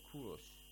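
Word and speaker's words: Kurosh (کوروش): For example, some of them mention Cyrus or Kurosh, as it's pronounced in Persian language, or Zartosht.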